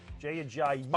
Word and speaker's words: Ajayi: But Jay Ajayi. I always forget the third syllable. – Okay. – Thank you. Jay Ajayi.